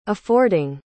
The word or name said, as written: affording